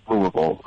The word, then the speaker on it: Louisville: But I mean, for people that live here, it's it's Louisville or Louisville, like removal. You know, like some people drop more than one uh, syllable.